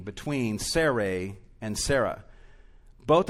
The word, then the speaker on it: Sarai: Then God said to Abraham, and I'm going to, I haven't been doing this all of these months, but I'm going to now pronounce Sarah's name the way it actually is in the Hebrew. Um, as for Sarai, your wife. That's how her name has been pronounced uh, up to this point of Genesis, even though we've been saying Sarah for convenience sake.